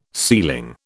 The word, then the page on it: ceiling: British English